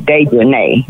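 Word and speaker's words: Dejanae: Her name is Dejanay. Yeah, that's Dejanay. It. It's Dejanay because it's got A-E on mm-hmm. the end. You're right. Dejanay Jackson. Then you've mm-hmm. got Winter Donovan, and it's spelled W-E-N-T-E-R.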